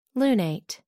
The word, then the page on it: lunate: American English